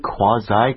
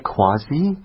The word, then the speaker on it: quasi: Have I been saying it incorrectly? Quasi, quasi? – I think both are okay. – Okay, yeah. That's awesome to know both are acceptable.